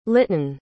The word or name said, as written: litton